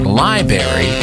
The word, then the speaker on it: library: Pronouncing it library is also correct, because the first R is just being quiet on account of being in a library.